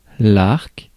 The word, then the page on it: edge: /ɛdʒ/; [ɛdʒ]